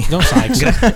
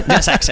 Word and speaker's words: John Sykes: John Sykes, spero si pronunci così. – John Sykes. – John Sykes, hai capito? – John Sykes.